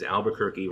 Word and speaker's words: Albuquerque: And that just stuck in my head as Albuquerque rhymes with turkey.